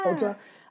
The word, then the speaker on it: Ulta: Ulta.